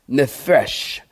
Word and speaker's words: nephesh: This word living is the Hebrew word nephesh. Nephesh. And it means, or it's spelled N-E-P-H-E-S-H.